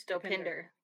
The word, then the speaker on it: Dopinder: I think it's Dopinder.